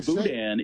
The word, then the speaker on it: boudin: Well, and by the way, boudin is a silent N.